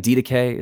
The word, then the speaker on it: Didache: So you would say with with confidence on this document, the Didache. Am I saying that right? – Yeah, I think DDK is how you pronounce it.